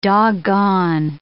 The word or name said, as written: doggone